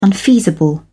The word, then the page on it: unfeasible: British English